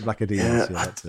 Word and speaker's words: Vlachodimos: No, it's the one that people think sounds like Shakadimus and Pliers. – Yeah, Vlachodimos. – Vlachodimos. – Yeah. That's it, Vlachodimos.